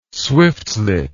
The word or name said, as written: swiftlet